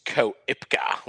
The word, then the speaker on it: Koepka: Yeah, look, I thought Koepka might have gone a bit better, but mate, it's Koepka. – No, it's Koepka. it's actually Koepka. Like how do you get Koepka? There's an O in there, right? Why don't we pronounce the O? – But you're not gonna Ricky?